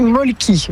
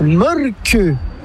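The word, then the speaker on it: Mölkky: Inventé en Finlande en 1996, ce jeu de quilles s'écrit M-O-T-R-E-M-A-L-K-K-Y. – Ça s'écrit Molki, mais ça se prononce Molke. – Le Molke. – Plutôt un E qu'un E, mais pas un U. Comme ça fait Molke.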